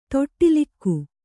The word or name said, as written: ತೊಟ್ಟಿಲಿಕ್ಕು